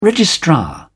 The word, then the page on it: registrar: British English